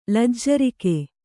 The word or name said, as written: ಲಜ್ಜರಿಕೆ